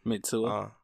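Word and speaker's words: Mitsuwa: It was almost similar to like Mitsugua or whatever it's pronounced. – Mitsua.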